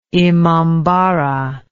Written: imambarah

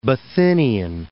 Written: Bithynian

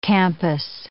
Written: campus

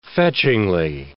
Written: fetchingly

